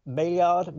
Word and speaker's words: Maillard: How should I say it, though? – Tomato. I'm not, Maillard reaction, M-A-L-L. M-A-I-L-L-A-R-D, Maillard reaction.